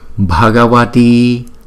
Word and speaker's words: Bhagavati: Ôm Padmini, Padmini, Padmini, Bhagavati, Bhagavati. Chữ BH chúng ta đọc là B. Chữ B không thì chúng ta đọc là B. Chữ BH là B.